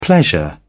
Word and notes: pleasure: British English

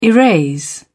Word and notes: erase: British English

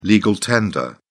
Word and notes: legal tender: British English

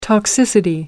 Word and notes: toxicity: British English

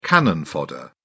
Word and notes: cannon fodder: British English

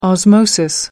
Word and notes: osmosis: American English

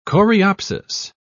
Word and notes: coreopsis: British English

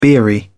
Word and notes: beery: British English

